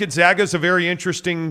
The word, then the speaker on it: Gonzaga: And by the way, I got the high-level explanation that it is Gonzaga. – Gonzaga. – I got like 10 people who are like, hey, man, I paid for my degree from Gonzaga, and I'd appreciate it if you'd pronounce it the right way.